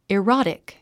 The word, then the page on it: erotic: irάtik; イロォティック